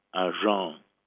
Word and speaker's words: Agen: There is a town in southwest France called, I always said Agen, but it's not Agen, it's pronounced Agen, A-G-E-N, Agen.